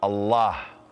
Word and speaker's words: Allah: It is pronounced A-double-L, exhale with love, Allah.